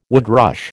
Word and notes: woodrush: American English

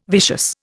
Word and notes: vicious: Indian

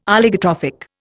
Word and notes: Oligotrophic: Indian

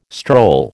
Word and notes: stroll: American English

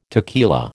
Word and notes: tequila: American English

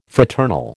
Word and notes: fraternal: American English

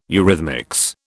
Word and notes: eurhythmics: British English

